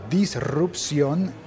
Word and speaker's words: disrupción: Porque descubrí que llevo un montón de tiempo diciendo disrupción y que se escribe con una sola R. R, disrupción, así como hablan los italianos, disruptivo.